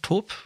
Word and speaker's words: Taupe: äh, äh, äh, Im Zweifelsfall sagen wir dann einfach äh, Taupe oder wie? Top? Wie wird das ausgesprochen? Top, oder? – Mm, top, ja.